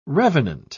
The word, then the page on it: revenant: British English